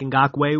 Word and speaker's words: Ngakoue: There's two ways to say this, Ngakoue or Nku. How do you say his name? – I just call him Yannick Ngakoue. – There you go, Yannick Ngakoue.